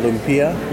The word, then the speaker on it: lumpia: They use a lumpia skin, which is a Vietnamese skin that fries up this way. – Oh, okay. Well, how do you say that? – Lumpia. – Lumpia? – L-U-M-P-I-A.